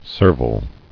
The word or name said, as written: Servility